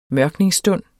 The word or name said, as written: mørkningsstund